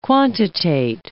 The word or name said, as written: quantitate